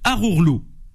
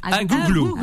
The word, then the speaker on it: Agouglou: Il m'a mis Arourlou. En français, je le prononce bien. Comment vous dites? – Agouglou. – Voilà, Manu, arrête avec tes phonétiques là.